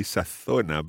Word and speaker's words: sazona: Yeah, this is the funny thing, because I would say sazona, but you would say? – Sazona. – And I think what we'll do is use Latin American pronunciation here because it doesn't really work as a tongue twister if we say sazona, ¿no? – Exacto, para eso es el tongue twister.